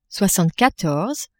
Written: soixantequatorze

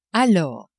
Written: alors

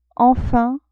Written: enfin

